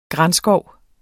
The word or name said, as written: granskov